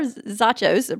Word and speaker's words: Zachos: I love her name, Zachos. I hope I'm saying that correctly. – I think you are. Zachos rhymes with nachos.